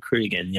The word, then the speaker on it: Cregan: It, is it Cregan? Did I say that right? It's, uh, yeah, Cregan. Yeah, Kenzo Cregan.